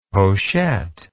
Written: pochette

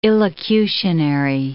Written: illocutionary

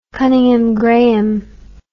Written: Cunninghame Graham

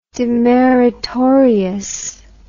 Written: demeritorious

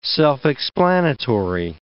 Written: self-explanatory

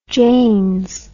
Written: janes

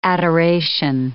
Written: adoration

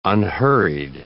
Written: unhurried

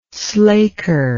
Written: slaker

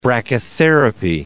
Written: brachytherapy

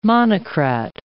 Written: monocrat

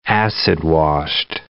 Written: acid-washed

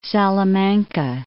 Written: Salamanca

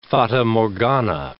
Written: fata morgana

